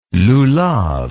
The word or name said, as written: lulav